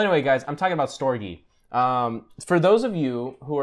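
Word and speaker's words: storge: It's the word storage there on when you spell it out in English. In Greek or Latin? – Latin. – It's storgy. – No. It is Greek. It's Greek. It's Greek. Yeah. – In Greek, it's storgy.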